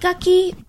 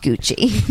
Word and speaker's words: Gucci: It looks like Gucci, but it's pronounced Gucci.